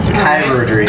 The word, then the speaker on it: chirurgery: This actually points out the correct pronunciation. – Chirurgery. – Chirurgery needed.